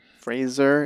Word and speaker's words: Fraser: Fraser is how you pronounce his name, is what I learned.